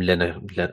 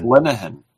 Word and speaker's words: Linehan: I think when you're talking about Graham Linehan, I can't pronounce his name, but... – it's just Linehan. That's all.